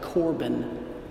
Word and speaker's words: Korban: These types of sacrifices are called Korban. You can look this up, K-O-R-B-A-N. In Mark 7, they spell it C-O-R-B-A-N.